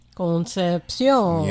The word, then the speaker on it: Concepcion: And his real name was Frenchie Concepcion. Did I say it right? – Concepcion.